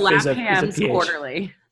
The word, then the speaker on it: Lapham's Quarterly: Latham's Quarterly? – Latham's Quarterly. – Okay. It, now, for those listening at home, it's spelled Lapham. – Yeah, so the F is Lapham's Quarterly.